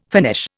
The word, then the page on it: finish: Indian